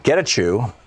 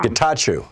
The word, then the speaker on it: Getachew: And on the line with us is Yosef Getachew with Common Cause.